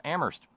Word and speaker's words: Amherst: Thank you very kindly to Terry in Amherst, where only the H is silent.